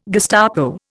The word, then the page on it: Gestapo: Indian